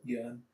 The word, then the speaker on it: Yeun: Is it Yoon? How do you say it? – Yeon. – Steven Yeun. – Oh, you told me like Yeon forever. – Steven Yeun. Yeah.